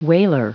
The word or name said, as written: wailer